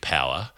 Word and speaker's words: Pougher: The remarkable wicketkeeper Dick Power, who we've also spoken about before, whose people suggest that the pronunciation of P-O-U-G-H-E-R should not be Power. I contest that. I think that is the only way to pronounce it, even if you could come up with an alternative.